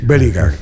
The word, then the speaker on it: Bellingham: Bellingham, sí. – Bellingham, sí.